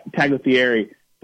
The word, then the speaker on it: Tagliaferri: It's been a whirlwind couple of weeks for Mr. Tagliaferri, but also the G is silent.